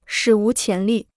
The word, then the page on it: 史无前例: shǐ wú qián lì